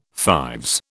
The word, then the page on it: fives: British English